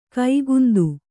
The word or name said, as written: ಕಾಕೇಂದು